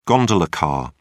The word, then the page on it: gondola car: British English